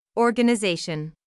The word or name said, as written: organization